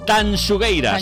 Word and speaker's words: Tanxugueiras: Sí, las chungueiras. ¿Lo he dicho bien? – Tanxugueiras. – Tanxugueiras. Me ha salido un chunguitos en gallego.